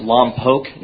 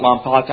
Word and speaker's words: Lompoc: And on a side note, it's actually pronounced Lompoc, not Lompoc. I was born there.